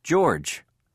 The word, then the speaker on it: George: C-H-R-I-S-T-O-P-H-E-R. – 1.7. – 1. What's your name? – George. – How do you spell it? – G-E-O-R-G-E.